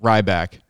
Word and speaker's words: Rybak: Is the former mayor's last name Rybak? Is that how you pronounce Ryback. – It? Rybak. – Yes.